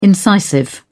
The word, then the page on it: incisive: British English